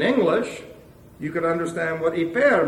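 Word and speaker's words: Hyper (ὑπέρ): You'll hear it pronounced by those who don't know how to pronounce the Greek language. Hooper. Okay? That's not hooper. It's hyper. Okay? Roll the rrr on the end.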